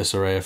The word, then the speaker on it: Saraya: Well, you will see where Saraya fits into all of this.